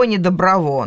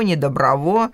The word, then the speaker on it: Недоброво: Плохово, недоброво. Но чтобы э, их а, ограничивать или отличать от соответствующих слов, да, они имели ударение на последний слог. Вот такого типа плохово, недоброво, дурново и так далее.